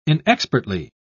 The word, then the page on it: inexpertly: British English